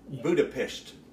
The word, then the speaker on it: Budapest: Budapest.